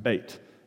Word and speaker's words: Beit: Second stanza, B, or Beit. Looks like Beth, it's pronounced Beit.